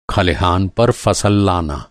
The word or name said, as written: खलिहान